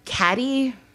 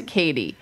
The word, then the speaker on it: Cady: Because they're like, Caddy? – Yeah. – And she's like, no, Katie. – It's Katie. It's spelled C-A-D-Y.